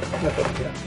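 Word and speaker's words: Mythopoeia: They always say it wrong. Mythopia.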